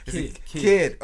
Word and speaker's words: DistroKid: What is it? Distro? Distro? Vistro or Distro? – Distro. – Distro. Spell it. – D-I-S. – Stro kit. – kid. – Oh, kid.